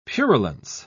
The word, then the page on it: purulence: British English